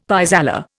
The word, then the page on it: pis aller: Indian